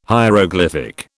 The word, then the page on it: hieroglyphic: British English